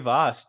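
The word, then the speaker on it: Vashj: it's just Lady Voss. Dude. The J is silent.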